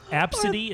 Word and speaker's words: Abcde: Absidy is how it's spelled or how it's pronounced. – Yeah, I've Absidy. Never. – It's However, not bad. – I know, but if you look at it, it's your first glance is ABCDE.